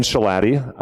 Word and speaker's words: Shalati: My name is Ben Shalati. My last name rhymes with Beef Patty.